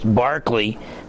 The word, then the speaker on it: Berkeley: Now, his name is spelled like Berkeley, but for some reason it was pronounced Barclay.